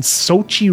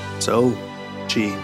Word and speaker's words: Sochi: That, the NHLers are going to be playing in Sochi, Russia. Ooh. Did I pronounce that right? – Sure. – Yeah. So we're going to be seeing the NHLers back in the Olympics. – Sochi.